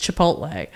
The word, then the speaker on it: Chipotle: And I'm like, oh no, the L is after the T. It's Chipotle.